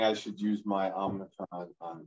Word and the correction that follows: Omicron: It's not Omnicron. It's Omicron. There's no N in there.